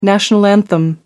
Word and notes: national anthem: American English

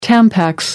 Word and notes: tampax: American English

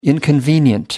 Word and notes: inconvenient: American English

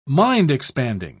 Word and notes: mind-expanding: British English

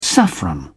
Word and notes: saffron: British English

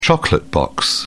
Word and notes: chocolate box: British English

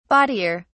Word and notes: bawdier: British English

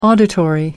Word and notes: auditory: American English

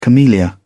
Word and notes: camellia: British English

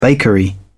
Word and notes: bakery: British English

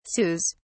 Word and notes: sous: British English

